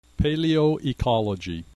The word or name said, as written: paleoecology